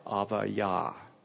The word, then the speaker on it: Abijah: So the way we read it in our translated Hebrew would be Abijah. But in Hebrew, that B specifically in this word would be pronounced as a V. Av, and the J would be pronounced as a Y. Avayah.